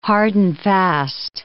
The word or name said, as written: hard-and-fast